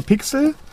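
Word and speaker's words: P!X3L: Genau, das schreibt sich also P Ausrufezeichen X3L. Spricht sich aber Pixel.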